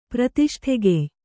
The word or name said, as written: ಪ್ರತಿಷ್ಠೆಗೆಯ್